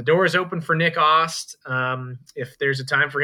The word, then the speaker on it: Ast: Thank you for correcting my pronunciation. It's Ost, Nick Ost. – Yeah, weird pronunciation. Looks like Ast, just spelled A-S-T.